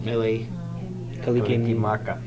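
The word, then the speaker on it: Mele Kalikimaka: Mele Kalikimaka.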